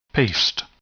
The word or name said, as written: paste